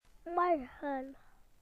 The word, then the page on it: tomorrow: British English